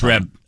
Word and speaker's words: Trèbes: Yeah, so that's the best we can get out of this small town called Trèbes, T-R-E-B-E-S. It's in southwestern France. – Trèbes, you don't pronounce the S. It's Trèbes.